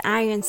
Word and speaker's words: Iron: Iron is spelled I R O N. But forget the letter O. It's just there to confuse us. It starts with I sound, which is called a long vowel. I, I.